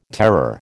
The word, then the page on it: terror: American English